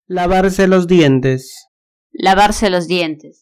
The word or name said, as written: lavarse los dientes